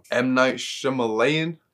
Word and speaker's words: Shyamalan: M. Night Shyamalan.